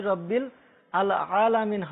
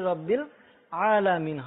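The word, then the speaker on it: রব্বিল আলামিন (رب العالمين): তো আলহামদুলিল্লাহি রব্বিল আল আলামিন হবে না, আলহামদুলিল্লাহি রব্বিল আলামিন হবে, ঠিক আছে ছোট বন্ধু?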